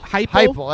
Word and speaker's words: Heupel: Heupel, that's how you say it. He has a funny spelling on his last name, but it's Josh Heupel.